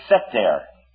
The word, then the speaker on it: seter: And the word for secret place here in the Hebrew is seter. S-E-T-E-R. Seter. We'll spell it here in the English for you, too.